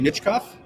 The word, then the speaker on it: Michkov: This is Michinov, right? That's Jerome Iginla, and that's the kid that everybody seems to be talking about today. – Matvey Michkov? – Yeah.